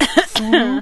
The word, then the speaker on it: Cthulhu: Cthulhu. – I believe it's pronounced Cthulhu.